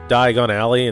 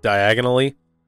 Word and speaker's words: Diagon Alley: This device is pretty cool, but I find it odd that Harry mispronounces Diagon Alley and says diagonally.